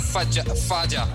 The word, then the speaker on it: Faja: It's Faja. It's F-A-J-A, Faja.